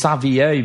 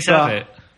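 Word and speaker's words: Saivet: I don't think it's Savier because it's S-A-I-V-E-T, so there's no like... – Isn't it V-I-E-T? – No, it's... – I heard someone on Sky Sports say Savier, but that does look like a mispronunciation.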